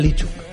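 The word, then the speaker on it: Lishchuk: Bueno, pues diga Lichuk. – Lichuk. – Qué bien pronuncia usted, Lichuk.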